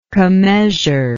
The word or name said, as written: commeasure